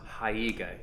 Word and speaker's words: haiga: that it's pronounced haiga, H-A-I-G-A, which is the visual representation that goes along with a haiku.